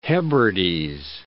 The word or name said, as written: Hebrides